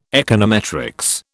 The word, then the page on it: econometrics: British English